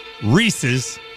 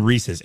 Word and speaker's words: Reese's: Anything, basically anything Reese's. And don't say Reese's. It doesn't rhyme with feces.